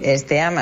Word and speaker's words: STEAM: Que no sé si lo digo bien, STEAM. – Sí, STEAM, así escrito, pues nada, basado en la ciencia, la tecnología, ingeniería, artes y matemáticas, es lo que significan las siglas en inglés.